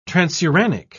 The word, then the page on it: transuranic: British English